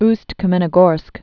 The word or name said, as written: Ust-Kamenogorsk